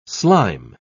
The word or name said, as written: slime